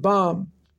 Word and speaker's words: bam: And the word about them is pronounced bam.